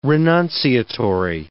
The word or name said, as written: renunciatory